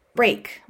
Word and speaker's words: break: Break rhymes with bake, but strangely not with reek. Break.